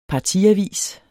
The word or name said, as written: partiavis